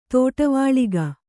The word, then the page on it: ದಿಟ್ಟಿವೆಳಗು: Kannada